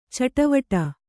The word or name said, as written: ಚಟುವಟ